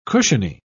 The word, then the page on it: cushiony: British English